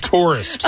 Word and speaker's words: tourist: I only hear one syllable in there. I'm telling you right now. – No, that's two syllables right there. Tourist. – Tourist. – I mean in the tour part.